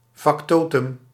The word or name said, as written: factotum